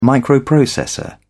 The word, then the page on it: microprocessor: British English